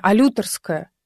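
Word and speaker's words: олюторская: Да, значит, олютерская. Алютерская? Алютерская. Подождите. Нету тут ударения. Нет, есть ударение. Алютерская. Вот, все. Говорите правильно, говорит Москва.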